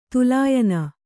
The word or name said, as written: ತುಲಾಯನ